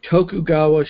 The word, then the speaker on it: Tokugawa: What we would call the historical epic, but they focus on a particular period in Japanese history called the Tokugawa Shogunate. That's a period from. I hope I'm pronouncing it somewhere close to the. – I think you're right.